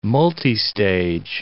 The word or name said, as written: multistage